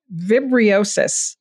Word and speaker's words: vibriosis: Let's move on now and we'll talk about Vibriosis.